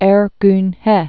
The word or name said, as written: Ergun He